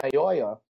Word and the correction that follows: Mayowa: Yes. Excuse, uh, Mayowa. I got to get that pronunciation. You got right.